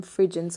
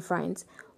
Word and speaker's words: Frijns: I used to call him Frigens because it's spelled as F R I J N S, but it's Robin Frijns.